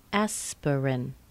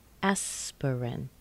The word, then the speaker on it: Aspirin: Aspirin. Aspirin. We've got a primary stress plus a weak stress plus an R. Aspirin. Aspirin. That R is connected with the consonant right in front of it, and it squeezes out the vowel.